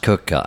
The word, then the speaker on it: Quooker: It's actually pronounced Quooker. I know it's spelt Quooker, but it's pronounced Quooker.